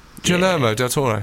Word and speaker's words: Guillermo del Toro: Guillermo del Toro. Is that how you pronounce it? Guillermo del Toro for The Shape of Water.